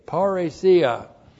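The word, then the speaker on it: parresia: Looks like that in the Greek P-A-R-R, long E-S-I-A, paresia.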